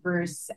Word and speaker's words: Gruss: So my last name, it rhymes with Seuss, but it's spelled differently. So it's G-R-U-S-S, but all of my social handles are Heidi Bruce.